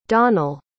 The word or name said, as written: donal